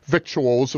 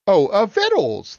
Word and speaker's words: victuals: Victuals, of course. I was corrected by my my friend Kanita said, what? Vittles. The word is vittles. And it's to me, no, it's victuals. It's written as victuals.